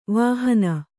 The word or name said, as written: ವಿಹೀನೆ